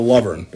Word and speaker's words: Lovorn: I'm talking to, I should know how many years you've been the coach, but longtime Cooper coach, Kyle Lovern. Did I get pronounce it right? – That's exactly right.